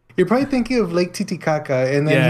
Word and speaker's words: Titicaca: Lake Titicaca. – No, say it in the proper pronunciation. – Titicaca.